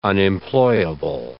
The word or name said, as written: unemployable